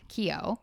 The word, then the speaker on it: Keough: Yeah, we looked it up because it's spelled like K-E-O-U-G-H. And we're both like, I have no idea how to say that. So one of Keo.